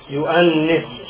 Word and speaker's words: Yuanis: But It's like a long E. Yuanis. The E in Coptic is a short E. Like Efgo. Efgo. There's an old Efgo. Yuanis. You see there's a longer sound there.